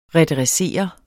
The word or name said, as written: redressere